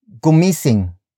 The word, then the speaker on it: gumising: For example, gumising. Gumising. Gumising. Gumising is spelled as G-U-M-I-S. I-N-G.